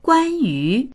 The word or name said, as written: guan1yu2